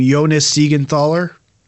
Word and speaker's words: Jonas Siegenthaler: You know, they on the left side, they have Michael Kempney, Dmitry Orlov, and I'm going to try to say his name, Jonas Siegenthaler.